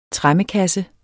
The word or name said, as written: TREMMEKASSE